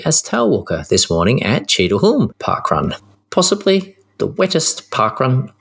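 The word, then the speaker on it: Cheadle Hulme: But I think it's Cheadleholm. I've got a feeling we've been told that before. – Yeah, Cheadleholm. – That's a silent L.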